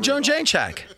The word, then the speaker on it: Jędrzejczyk: But the woman that Zhang just fought, the Polish fighter, Joanna Jędrzejczyk, that one, don't even try. I don't even know if I'm saying it right. – Yeah, Joanna Jędrzejczyk.